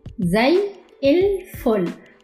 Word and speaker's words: زي الفل: زي الفل. Okay? So there is فاء here. الفل. زي الفل. But we say it faster, so we say it like that way.